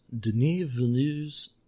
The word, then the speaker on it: Denis Villeneuve's: Yeah, so next pod will be about Denis Villeneuve's Dune.